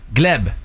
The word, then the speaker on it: Gleb: Gleb, c'est comme ça qu'on prononce son nom.